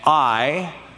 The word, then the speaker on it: Ai: and Ai, that's how Ai is pronounced, Ai on the east.